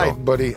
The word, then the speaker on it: Lightbody: E il cantante si chiama Gary Lightbody. Quindi corpo lucente. Cos'è questo? Gary Lightbody. Gary corpo leggero. – Lightbody. Ah, correggi l'accento adesso. Adesso mi correggi l'accento.